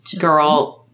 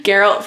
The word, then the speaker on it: Geralt: Your Murderbot, Joel from The Last of Us, Geralt. – Oh, no! – You got me saying it wrong now. Geralt from The Witcher,